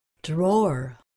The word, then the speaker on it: drawer: Drawer, drawer. The W isn't pronounced, not drawer, drawer.